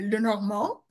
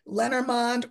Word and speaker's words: Lenormand: Well, it's French. So in France, they would say Lenormand, but we're not in France. – Yeah. – So we are allowed to say it however it comes out of our mouth. So most people will say Lenormand or Lenormand, whatever it looks like, just spell it up, you know, sound it up phonetically and you'll be correct.